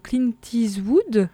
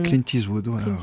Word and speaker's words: Clint Eastwood: Alors là, cette fois, tu nous parles du groupe Clint Eastwood, prononcé comme ça, hein, c'est ça? – Oui, Clint Eastwood.